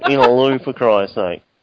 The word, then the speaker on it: Innaloo: that's 6 hours away from us, and the suburb's called Innaloo, and it's spelled In-a-loo.